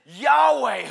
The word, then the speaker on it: Yahweh: There's a lot, and Jehovah's a mispronunciation, it's Yahweh.